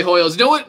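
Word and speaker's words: Hoiles: H-O-W-L-E-S? – I think it's Hoyles. – It's H-O-I-L-E-S. – I'm saying Hoyles. – Yeah, I'm going to go ahead and say Hoyles.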